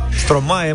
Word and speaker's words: Stromae: doar așa să zicem, piesa mea e mult mai nouă, vine de la o trupă care se cheamă Strom Strome. Stromae, mă. Strome. – Stromae. Așa scrie, așa citim. Strome. Da, Stromae. da.